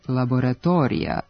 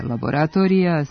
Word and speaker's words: laboratorija: Pošto akcenatska norma ne dopušta silazne akcente van prvog sloga u reči, pogrešno je izgovarati laboratorija sa dugosilaznim akcentom na četvrtom slogu. Jedino ispravno biće laboratorija sa kratkouzlaznim akcentom na slogu ispred i neakcentovanom dužinom na mestu na kome je bio preneti dugosilazni akcenat.